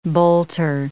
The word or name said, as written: bolter